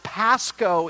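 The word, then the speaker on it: Pasco (πάσχω): The word for suffer is pronounced Pasco in the Greek, and the word for Passover is Pascha.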